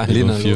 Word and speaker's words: Linalool: Ja, Linalool. 4VG. – Ja. – Das Doppel-O ist immer ein bisschen interessant.